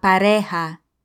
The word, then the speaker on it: pareja: La pareja.